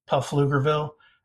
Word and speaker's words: Pflugerville: We tried to put on a film festival in a suburb of Austin named Pflugerville with a silent P, Pflugerville.